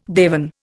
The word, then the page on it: dewan: Indian